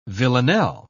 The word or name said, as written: villanelle